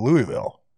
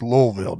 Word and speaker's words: Louisville: Did you say you pronounced that horribly? – He said, yeah. I said, well, the first thing you need to do is get that word out your mouth. I said, it's Louisville down there.